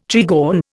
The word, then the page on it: trigon: Indian